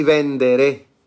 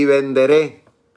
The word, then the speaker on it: venderé: Y vendré a vosotros, el Señor dice, y vendré, perdón, y vendré vuestros hijos y perdón, perdón, y venderé, con acento en la E, y venderé vuestros hijos, le dice el Señor, en este caso a las naciones que fueron injustas contra Israel.